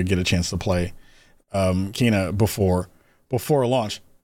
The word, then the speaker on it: Kena: I keep saying it wrong. Kena. And it was super cool.